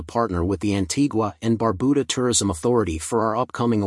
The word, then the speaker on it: Barbuda: Antigua, pronounced in TGA, and Barbuda, Barbuda, is located in the heart of the Caribbean Sea.